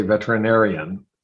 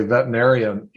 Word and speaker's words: veterinarian: You know how to say veterinarian. So many people say veterinarian. It's so hard to say. It has six syllables.